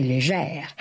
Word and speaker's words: Léger: Roy et Léger, prononcé légère, à l'ancienne, comme du temps où le français accentuait le R final.